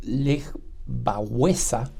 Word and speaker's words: Lech Wałęsa: Probablemente el nombre de Lech Walesa, o como se pronuncia en polaco, Lech Wałęsa,